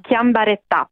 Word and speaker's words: Chiambretta: Tu hai intervistato l'architetto Chiambretta, come si vuol far chiamare? Alla francese o all'italiano? – No, col italiano, quindi è Chiambretta. comunque un po' francese, mettiamo l'accento sulla A alla fine.